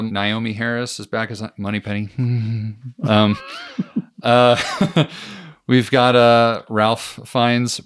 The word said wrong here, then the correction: Ralph: back as M, or Rafe. Is it Rafe? – It's Rafe.